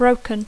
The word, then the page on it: broken: English